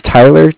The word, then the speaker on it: Tyler: All right, my name is Tyler, T-Y-L-E-R. And I'm calling with Cardiff. It's spelled C-A-R-D-I-F-F, as in Frank.